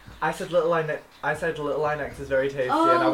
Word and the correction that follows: Lil Nas X: Yeah, it's Lil Nas X.